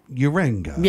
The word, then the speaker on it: Yeranga: The Australian Federal Police and New South Wales Police have launched a massive public appeal under Strike Force Yeranga. Is that how you say it? Yeranga. – Yeranga. – Yeah.